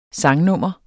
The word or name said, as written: sangnummer